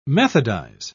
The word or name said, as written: methodize